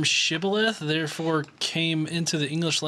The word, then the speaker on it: Shibboleth: they could not pronounce the SH sound, sh. So when they asked them to say Shibboleth, if the person who was trying to cross the river said Sibboleth and not Shibboleth, then he seized him and killed him in the fords of the Jordan.